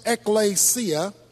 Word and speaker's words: ekklesia: The word church is taken from a Greek word pronounced ekklesia. And the Greek term ekklesia means called out ones or an assembly. But in New Testament theology, in fact, in New Testament usage, the word ekklesia,